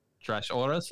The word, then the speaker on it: Trashorras: is how you would pronounce that trash auras.